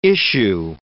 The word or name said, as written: issue